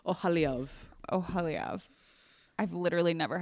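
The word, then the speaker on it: Oholiab: It's because it's, Aholyov. It's two kamats, like, in a row.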